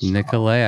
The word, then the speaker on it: Nicolet: Is that how you say that? – That's how you say it. So it's French. Yeah. Nicolet is French. – Nicolet. I got it right.